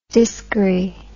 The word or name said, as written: diskery